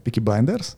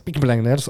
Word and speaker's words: Peaky Blinders: Peaky Blinders, ako sa to povie? – Peaky Blinders? – Peaky Blinders, OK.